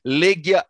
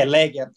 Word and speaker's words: Legia: Mas Legia é a pronúncia correta do clube. – É, Legia. Aqui, aqui no, na Polônia eles costumam falar Legia.